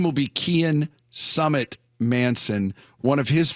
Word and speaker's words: Cian: C-I-A-N. How would you pronounce that? – C-I-Cain? – Yeah, I would go with Sean because it looks, it's actually pronounced Kian.